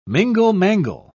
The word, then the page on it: mingle-mangle: British English